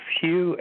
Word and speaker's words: Hugh: But, yes, my name is Hugh, H-U-G-H. The last name is Trawlson. That's spelled T like Tom, R-A-U-L-S-E-N like Nancy.